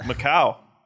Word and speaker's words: Macau: Macau.